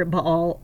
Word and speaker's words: Baal: Now, we know from the Old Testament that this area was once the center for, as I said, Baal worship, B-A-A-L, pronounced either Baal or Baal.